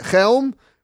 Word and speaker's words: Chelm: Just to tell the listeners, it says here, Chelm, pronounce like you have something stuck in your throat.